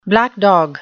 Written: black dog

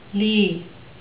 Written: lea

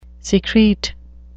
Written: secrete

